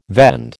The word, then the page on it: vend: American English